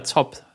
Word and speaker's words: ZOB: Ähm, und dann waren wir nicht am, äh, am, am ZOP, spricht man das so aus? ZOB, Om Omnibus Bahnhof? Ja, ZOP, sagt man.